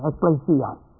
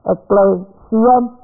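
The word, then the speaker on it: ekklesia: Transliterated, E-K-L-L, long E, S-I-A, Ecclesia. Ecclesia, it's a compound word from the prefix ex, E-K, which means out of, plus to call out.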